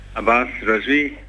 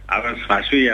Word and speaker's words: Abbas Razvi: Abbas Razvi. Abbas Razvi, er der mange, der udtaler mit navn som sådan.